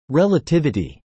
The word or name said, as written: relativity